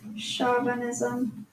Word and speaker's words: chauvinism: Chauvinism. – Chauvinism? – Sorry, the pronunciation right here says sha.